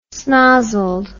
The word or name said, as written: snozzled